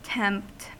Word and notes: tempt: American English

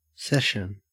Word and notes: session: Australian English

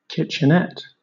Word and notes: kitchenette: British English

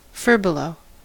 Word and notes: furbelow: American English

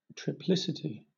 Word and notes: triplicity: British English

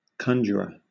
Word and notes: conjurer: British English

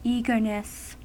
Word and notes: eagerness: American English

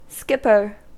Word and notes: skipper: American English

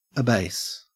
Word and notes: abase: Australian English

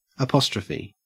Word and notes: apostrophe: Australian English

